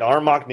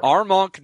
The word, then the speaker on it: Armonk: Is it Armonk? Armonk? – That's right. Armonk, New York. – Armonk, New York.